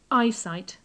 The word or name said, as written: eyesight